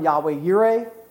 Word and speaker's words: Yahweh Yireh: So I use the term Yahweh Yireh because it's the more appropriate pronunciation, and so that's what you'll hear me say.